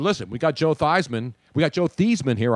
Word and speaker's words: Theismann: But they didn't want to put Theismann, so they changed it to Theismann, which rhymed with Heisman.